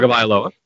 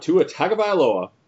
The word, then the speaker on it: Tagovailoa: that Tua Taglio. I got. I can never pronounce his last name. Tagovailoa. – Tagovailoa. Tua Tagovailoa.